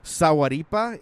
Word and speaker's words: Sahuaripa: This is called the, I would pronounce it Sahuaripa. It's like saguaro and IPA put together, okay.